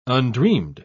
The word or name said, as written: undreamed